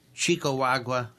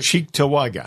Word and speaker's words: Cheektowaga: At the Knights Event Center, 2735 Union Road, Chicawagua. Is that how you pronounce it? – Cheektowaga. – Cheektowaga? – Cheektowaga.